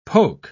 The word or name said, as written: poke